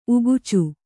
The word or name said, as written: ಉಕ್ಕಾಚು